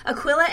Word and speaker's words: Aquila: Aquila and Priscilla, that you too were like them.